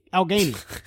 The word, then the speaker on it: Allegheny: If you are ever in Pittsburgh, you will have to check out Allegheny Smokeworks. Help me out. – Allegheny. – It's my local pipe one-stop shop. Well, Pittsburgh's a beautiful place. I'd love to go back there. But Allegheny.